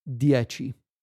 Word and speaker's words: dieci: In proper Italian pronunciation, and I'm going to try here my best, is dieci.